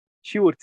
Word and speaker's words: Sjoerd: Let's say your name again, Sjoerd.